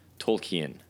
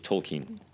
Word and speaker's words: Tolkien: But uh, North Americans really want to say, and, and, you may catch me doing it, um, ending it like the word Canadian, Tolkien. Uh, you'll you'll you'll catch uh, North Americans doing that a lot. But the pronunciation for for uh, and we should be trying to get it right is Tolkien.